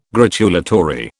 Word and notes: gratulatory: British English

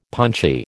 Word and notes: punchy: American English

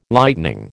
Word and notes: Lightening: American English

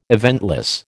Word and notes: eventless: American English

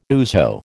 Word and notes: ouzo: American English

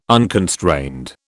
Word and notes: unconstrained: British English

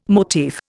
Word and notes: motif: Indian

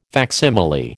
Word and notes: facsimile: American English

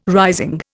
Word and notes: rising: Indian

